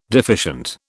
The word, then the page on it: deficient: British English